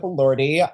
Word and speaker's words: Elordi: Elordi? How do you say it? – Yes. Well, Jacob Elordi.